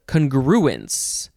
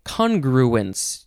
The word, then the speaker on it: congruence: Okay, next is congruence. You could also say congruence. You can emphasize the first or the second syllables.